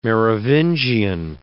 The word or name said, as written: Merovingian